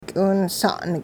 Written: k'iwinsa'n

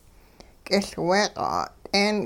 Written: kiLwee-q' 'a't'een

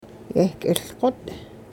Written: yehk'iLqot'

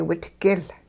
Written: witgiL

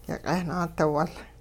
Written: yiq'eh-naadiwul

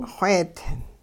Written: xweedi

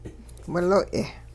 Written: wiloy'

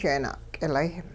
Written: jeena'k'ilay

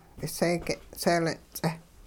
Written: k'iseege' seelin-ts'eh

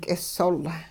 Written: k'isol

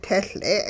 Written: tehL'e'

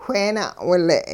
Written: wheena'wile'

